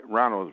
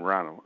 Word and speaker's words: Ronald: Ronald is my first name, Ronald. R-O-N-A-L-D.